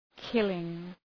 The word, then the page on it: killing: ‘kılıŋ